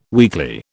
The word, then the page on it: weekly: British English